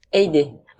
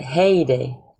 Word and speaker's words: Haydée: D'ailleurs, je, prononce Aïdé ou Aidé en fait Aidé. En fait, je crois qu'il y a plusieurs prononciations parce que ma mère est irlandaise, c'est elle qui m'a appelé comme ça. Et elle m'appelle Heyday.